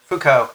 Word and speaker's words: Foucault: Foucault.